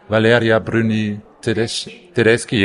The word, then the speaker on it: Valeria Bruni-Tedeschi: Is that how you pronounce it? Valeria Bruni-Tedeschi, yes.